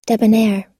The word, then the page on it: debonair: er